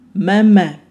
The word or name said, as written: maemae